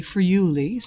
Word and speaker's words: Friuli: You are from a specific section of Italy, Friuli. See, am I saying it correctly? – Yes.